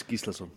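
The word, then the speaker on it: Gíslason: It's Gislason.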